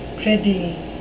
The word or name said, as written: prithee